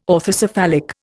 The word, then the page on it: orthocephalic: Indian